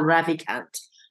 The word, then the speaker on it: Ravikant: I hope I pronounce him correctly, but it's Naval uh, Ravikant. – Naval Ravikant.